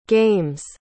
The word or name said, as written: game's